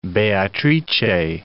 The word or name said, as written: Beatrice